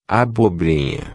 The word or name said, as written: abobrinha